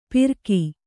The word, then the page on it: ಪಿರ್ಕಿ: pirki